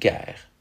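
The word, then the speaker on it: guerre: Today, those words are pronounced gaip and gair.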